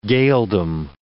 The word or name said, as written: Gaeldom